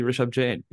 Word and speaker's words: Rishabh Jain: Okay, because the emphasis is what throws me off, because it's the emphasis on the sh, not the Rishabh. It's not Rishabh, which is how I would naturally pronounce it. It's Rishabh.